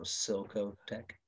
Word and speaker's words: Cilcotech: Am I saying that correct? Silco. – Like silicone. – Silk. – So Silco Tech. – How do you spell that? – C-I-L-C-O-T-E-C-H. Silco Tech. – See, I wouldn't have got that. – Yeah. It's like silicone.